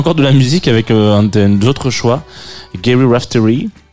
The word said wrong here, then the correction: Rafferty: Raftery non, je sais pas, j'ai loupé. – Rafferty. C'est comment on le prononce Si, si, si, c'est ça. – Rafferty, c'est moi qui sais juste pas lire, excusez-moi.